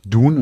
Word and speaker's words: Dune: Es heißt nämlich Dune und nicht Dune.